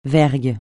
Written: vergue

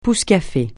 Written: pousse-café

